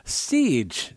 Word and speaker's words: Cej: Siege, just prior to that, I finally learned how to say his name. He spelled it phonetically for me in an email. Siege, as in like siege, S-I-E-G-E, but it's actually spelled C-E-J.